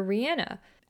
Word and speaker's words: Rihanna: But I started to think like, wait, didn't she say that her name is actually pronounced Rihanna?